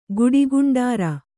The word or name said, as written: ಗುಡಿಗುಂಡಾರ